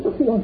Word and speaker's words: cúng dường: Thật ra chữ cúng á, nguyên chữ tàu là chữ cung, nhìn đạp xạ đi gọi là cúng. Rồi chữ dường á, nguyên chữ tàu đọc là dưỡng, mình đọc là dường.